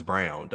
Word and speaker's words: Braun: It's pronounced as Brown, though. That was something new I found out. I was like, I know it felt like Braun, but it's pronounced like Brown.